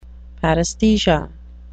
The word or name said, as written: paresthesia